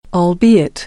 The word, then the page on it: albeit: American English